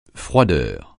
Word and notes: froideur: francais

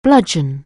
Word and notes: bludgeon: anglais

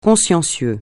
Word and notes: consciencieux: francais

